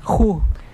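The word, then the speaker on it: juj: Entonces, vamos a aprender a escribir en Yucatán la iguana, que se pronuncia ju. ¿Estamos listos? – Así es. – Pues es, ju se escribe con la letra J. La letra U. – U. – Y la letra J. – J. La letra, así de fácil.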